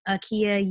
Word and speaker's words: Akiea: So, that said, otherwise you can find me at Woke Kindergarten on Instagram or at Akiea, U-H-K-I-E-A. I spell it phonetically for all of the white folks who have always called me IKEA, even though my name starts with an A. And A makes more than one sound.